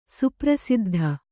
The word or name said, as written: ಸುಪ್ರಸಿದ್ಧ